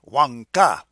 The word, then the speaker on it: Wangka: Yeah, W-A-N-G-K-A, Wanka, with the accent on the big A.